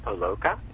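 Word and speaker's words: Palahniuk: And by the way, Chuck, let me just ask you, am I saying your name right? – It's actually pronounced Palaka.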